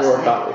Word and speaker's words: Morikawa: I can't ever pronounce his last Morikawa.